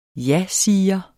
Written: jasiger

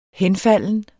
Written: henfalden

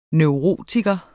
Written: neurotiker

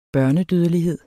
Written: børnedødelighed

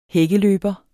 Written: hækkeløber

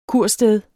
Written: kursted

